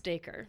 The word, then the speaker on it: Dacre: My favorite, actually, and this is going back to Stranger Things, I think Doc Ray Montgomery. – That was mine, and it's apparently pronounced Dacre.